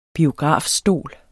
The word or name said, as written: biografstol